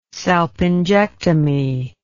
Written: salpingectomy

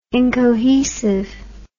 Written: incohesive

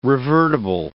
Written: revertible

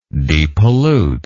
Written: depollute

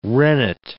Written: rennet

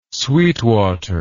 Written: sweetwater

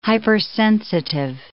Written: hypersensitive

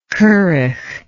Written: currach

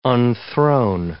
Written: unthrone

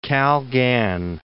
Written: Kalgan

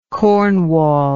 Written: Cornwall

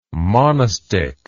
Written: monostich

